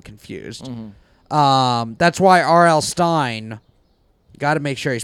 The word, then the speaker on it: Stine: Yeah, it's Stein, but it's spelled S-T-I-N-E, so it's like Palestine.